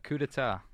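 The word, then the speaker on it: coup d'état: A coup d'etat. – A coup d'etat. – Is that how you pronounce it? – That's how you pronounce it, yeah.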